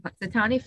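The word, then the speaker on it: Punxsutawney: Puxatunny Phil, I think that's how you say his name. – Puxatunny Phil, yep.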